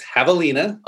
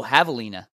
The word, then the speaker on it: javelina: I hope I'm pronouncing that right, but it's javelina. – Oh, javelina. – Yep. The J is the H sound.